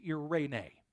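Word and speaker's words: Irene (εἰρήνη): That word assurance in the original language is irene. Irene is how that's pronounced.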